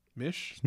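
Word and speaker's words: Misch: Uh, my song of the week is "Nobody's Fault" by Benny Sings and Tom with Smish. Mish. Mish. – I can never say his name. There's no S at the beginning.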